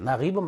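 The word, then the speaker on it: 나깁 (نجيب): 이렇게 연결형이기 때문에 이렇게 겹표시 사람들이 하기 힘들어하는데, 카타바 나지브 마후딘, 이집트식으로 발음하면 나깁이에요. 나깁 마후딘 리와야탄.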